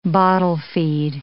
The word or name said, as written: bottle-feed